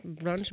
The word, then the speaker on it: brunch: Um, sí, cuando hice esa serie de, de los bronches, ¿Cómo se dice? Brunch. – Brunch, es un brunch.